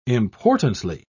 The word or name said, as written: importantly